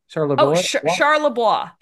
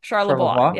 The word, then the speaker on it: Charlebois: Charlebois. Charlebois.